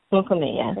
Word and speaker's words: Lukumi: So, you know, speaking of African dance, you also practice an African religion, and it's Lukumi. – Lukumi, yes.